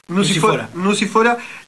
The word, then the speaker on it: Nucifora: Nucifora, sobre la instalación de un barrio en la zona del Cinturón Verde de La Plata, en las afueras de La Plata.